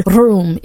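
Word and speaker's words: rum (רום): Now that word high is the Hebrew word rum. It's spelled R-U-M, which means to be exalted, to rise, or to raise.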